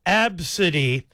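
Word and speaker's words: Abcde: Now, that's how it's spelled. The name is going to be pronounced Absidy.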